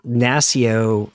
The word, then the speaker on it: NASCIO: Uh, you know, we had, during our preparation for this session, you mentioned an organization called, uh, I think, Ignacio. It was the Na- way NASCIO. – it's pronounced. – NASCIO.